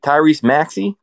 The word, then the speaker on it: Tyrese Maxey: And is it Tyrese Maxey? – That, yep.